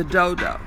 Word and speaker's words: Dodow: And again, it's called the Do-Do, but it's spelled D-O-D-O-W, in case you want to purchase it.